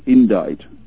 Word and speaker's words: Indict: To cover this question of Anne Clwyd, what I learned was that she was involved with a company called Indict, pronounced Indite in the dictionary, but it's all in capital letters here.